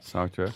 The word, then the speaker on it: Sartre: And then Sartre, is that how you say it? – Sartre.